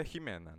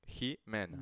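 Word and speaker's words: J Mena: Mas a pronúncia é Jimena, né? Jimena.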